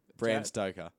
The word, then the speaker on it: Stoker: Bram Stokes. How do you say that name? – Bram Stoker.